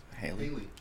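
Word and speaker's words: Halle: Um, if you guys have been living under a rock, your girl Haley or Holly, how do we pronounce it? Is it Holly? – Haley.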